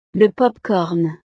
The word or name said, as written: le popcorn